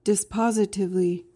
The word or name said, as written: dispositively